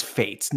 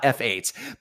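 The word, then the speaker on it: F8: I, I the consensus is that Five Finger Death Punch is, is pronounce fates, not f 8 Because Gretchen and I were in the car where we heard Grant Random on XM call it f 8 And that just doesn't make sense.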